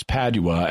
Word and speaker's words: Padua: In the interview, Bill pronounces Padua a couple of ways, but it's Padua, as in St. Anthony of Padua, that we're talking about.